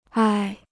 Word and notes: 挨: ái

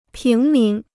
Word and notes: 平民: píng mín

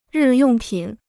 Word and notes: 日用品: rì yòng pǐn

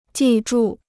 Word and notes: 记住: jì zhu

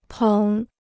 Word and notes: 捧: pěng

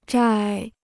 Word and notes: 债: zhài

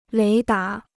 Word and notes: 雷达: léi dá

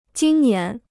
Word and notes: 今年: jīn nián

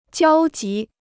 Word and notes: 交集: jiāo jí

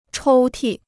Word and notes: 抽屉: chōu ti

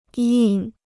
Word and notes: 印: yìn

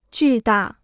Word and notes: 巨大: jù dà

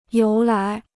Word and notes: 由来: yóu lái

